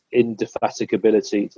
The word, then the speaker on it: indefatigability: his indefatigability. Did I pronounce that word right? Something like that. – You did, yeah.